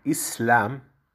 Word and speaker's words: Islam: A palavra Islam se pronuncia em árabe Islam, é uma palavra árabe que significa submeter e render a nossa vontade a Deus.